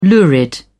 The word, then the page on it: lurid: British English